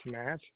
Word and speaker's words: Matt: That's Matt Eisenberg, I-S-E-N-B-E-R-G. – Okay. You said Matt? Your first name, Matt? – M-A-T-T, yes.